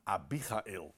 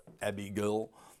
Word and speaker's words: Abigail: En Abby, en u zag het al eigenlijk op de eerste sheet die ik toonde, is afgeleid van de naam Abigail. Dat is de wijze waarop wij dat dan uitspreken. En, of Abigail, op zijn Engels.